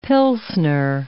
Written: pilsner

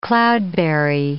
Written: cloudberry

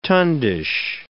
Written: tundish

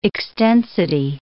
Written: extensity

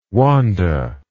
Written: Wanda

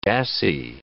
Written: dassie

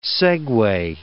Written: segue

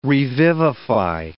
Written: revivify

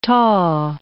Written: taw

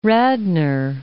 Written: Radnor

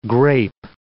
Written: grape

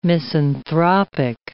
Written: misanthropic

